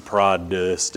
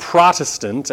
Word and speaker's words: Protestant: Right, and I know that we pronounce it like it's a D, prod-dest, but it's not, it's a T, because the word protest is right in there.